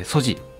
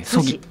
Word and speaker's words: SOGI: はい、 SOGI と書いてソジというふうに読みます。 – はい、ソギ、はい、ソギというふうに読む場合もあります。